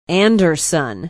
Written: anderson

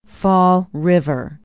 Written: fall river

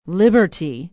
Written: Liberty

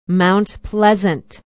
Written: mount pleasant

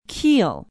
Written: kiel

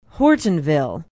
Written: hortonville

